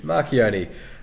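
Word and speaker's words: Marchionne: Okay, so the head of, or the CEO of FCA, Fiat Chrysler Automobiles, Sergio, you're a part of Italian, you pronounce that, Macchioni? – Let's have a look here, Sergio. Yeah, let's go. Marchioni. – Marchioni.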